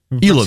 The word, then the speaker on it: Илон: где сейчас Алан Маск находится. И это... – Что ты его Аланом называешь? Он же... – Илон. – Всех СМИ Илон.